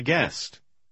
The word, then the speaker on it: guest: Тогда буква U обычно не произносится, а G звучит звонко. Попробуйте прочитать номер 6. – Be my guest.